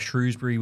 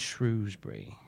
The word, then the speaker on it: Shrewsbury: And then you've got Shrewsbury, which isn't too far, or Shrewsbury, depending on where you're from. I always pronounce it, when I lived there, I always pronounce it Shrewsbury.